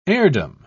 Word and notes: heirdom: British English

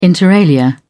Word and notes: inter alia: British English

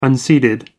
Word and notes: unseeded: American English

